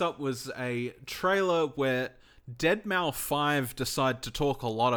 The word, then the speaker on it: Deadmau5: He said specifically in this thing, he said, hi, it's Deadmau5 here, and yes, that's how you pronounce it.